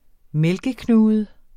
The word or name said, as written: mælkeknude